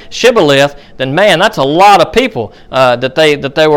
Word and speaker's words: Shibboleth: Instead of saying Shibboleth, they couldn't pronounce that sh, so they were just going to say Sibboleth.